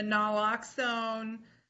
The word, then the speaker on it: naloxone: I don't know if you guys have also heard that drug makers have jacked up the NALA, and I'm terrible, who can pronounce this for me? N-A-L-O-X-O-N-E.